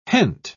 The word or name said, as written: hent